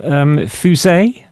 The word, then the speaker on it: Fusée: Fusée, is that how you pronounce it? – Yeah, exactly.